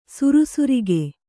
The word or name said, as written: ಸುರಸುರಿಕೆ